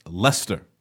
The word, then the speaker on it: Leicester: By the way, according to ABC News, it's pronounced Leicester.